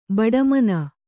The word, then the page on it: ಬಡಮನ: baḍa mana